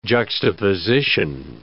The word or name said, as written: juxtaposition